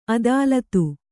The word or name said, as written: ಅದಾಲತು